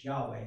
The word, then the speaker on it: Yahweh: Uh, Yod, it's, uh, it's YHWH, Yahweh is the way that we pronounce it in English, but, but that's the word that is there.